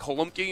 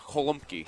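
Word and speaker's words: gołąbki: But it's like a G sound that you're doing, like the weird G. Yeah, holumki.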